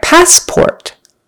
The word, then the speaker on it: passport: All right, passport. Passport. First syllable, stressed. Passport, not passport, but passport. And then make sure you get the O-R in port. Passport, not passput or passport. Passport, or rhymes with door.